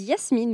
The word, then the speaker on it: Jasmin: Je m'appelle Yasmine et euh, j'ai 42 ans. Je suis euh, franco-allemande, donc c'est pour ça mon prénom euh, se dit Yasmine, mais il s'écrit Jasmin puisqu'il est orthographié en allemand.